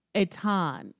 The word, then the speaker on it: Eitan: I am all about Eitan, E-I-T-A-N, the Hebrew spelling and pronunciation of Ethan.